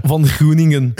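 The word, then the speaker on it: Van Groeningen: J'arrive pas à prononcer les noms belges. Van Groningen. Exactement.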